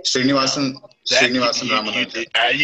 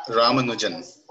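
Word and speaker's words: Srinivasa Ramanujan: Srinivasan Ramanujan. – You already knew who I was talking about. All right, Srinivasan. – Ramanujan.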